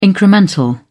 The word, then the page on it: incremental: British English